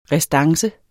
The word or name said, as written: RESTANCEN